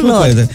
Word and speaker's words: Claude: El creador de Claude, ¿cómo se pronuncia siempre? – Claude. – Como que digo Cloud.